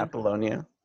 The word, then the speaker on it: Apollonia: A-P-O-L-L-O-N-I-A. – Can you pronounce it for me in Italian? – Apollonia. Oh, in Italian. Oh, in Italiano. Apollonia. Sante Apollonia. Mamma mia, Sante Apollonia.